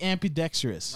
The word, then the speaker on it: ambidextrous: Did you say that right? – I said, please don't be ambidextrous. – Ampy? – I didn't think you said it right. – Bro, that word was ambi. – Bro, that word means that you could use both hands. – Yes, I know that. – You said ampy, though.